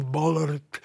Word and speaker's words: Ballard: píše se Ballard, čte se Bob Ballard,